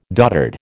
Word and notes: doddered: American English

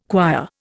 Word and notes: quire: Indian

